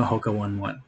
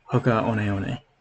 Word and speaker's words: Hoka One One: How they were like, everyone's calling it 1-1, so we're officially now Hoka 1-1. And they were like, no, Hoka 1-1's weird. Let's go back to how we originally intended our name to be pronounced, Hoka One One.